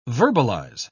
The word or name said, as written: verbalize